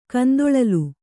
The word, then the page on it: ಕಂದೊಳಲಿ: kandoḷali